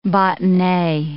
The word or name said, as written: botonnee